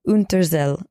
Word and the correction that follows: Unterzell: but it's Unterzell. I think it's pronounced with like a C.